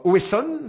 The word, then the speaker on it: Oesson: Propal ou encore Oesson, c'est ouais. bien comme ça ça se prononce. – Oesson, ouais. Hein, ouais Propage, ouais.